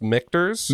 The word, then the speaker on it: Michter's: Imagine it's pronounced Michter's.